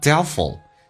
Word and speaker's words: doubtful: 那么Doubtful 那个 T 是可以不用发音的，当两个辅音在一起，那个 F-U-L 发 ful, 但是那个 a 也可以不用发音，在美式发音当中因为被 L 所吸收了，就变成 Doubtful on it, on 跟 it 有一个连音叫 on it, 如果你看起来皱眉头，你向他皱眉头看起来不是很确定， But if you frownThen look doubtful on it.